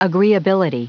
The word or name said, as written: agreeability